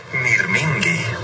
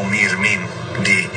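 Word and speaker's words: mirmingi (μυρμήγκι): Se dice mirmingi. Suena como mirmingi, más o menos.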